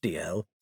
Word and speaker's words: Dalziel: "Pronounced D-L, spelt D-A-L-Z-I-E-L. It's a Scottish surname.